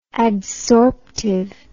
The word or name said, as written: adsorptively